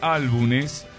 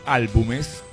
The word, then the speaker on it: álbumes: que editó 9 álbumes de estudios, álbumes, vamos a usar el término bien pronunciado, hasta la disolución, que esto ocurrió en el año 2001.